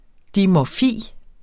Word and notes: dimorfi: [ dimɒˈfiˀ ]

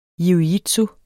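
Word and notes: jiu-jitsu: Danish

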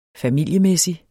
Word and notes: familiemæssig: faˈmilˀjəˌmεsi; faˈmilˀjəˌmεˀsi

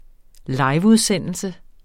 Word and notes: liveudsendelse: ˈlɑjv-

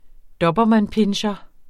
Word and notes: dobermannpincher: ˈdʌbʌˌmanˌpenɕʌ; -ˌpinɕʌ

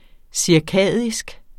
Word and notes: cirkadisk: siɐ̯ˈkæˀðisg; siɐ̯ˈkæˀdisg

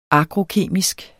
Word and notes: agrokemisk: ˈagʁoˌkeˀmisg; ˈɑgʁoˌkeˀmisg

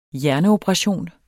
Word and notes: hjerneoperation: dansk

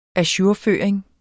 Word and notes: ajourføring: aˈɕuɐ̯ˌføːɐ̯eŋ; -ˌføˀɐ̯eŋ

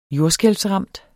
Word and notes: jordskælvsramt: ˈjoɐ̯sgεlvsˌʁɑmˀd

